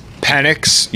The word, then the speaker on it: Penix: Penix.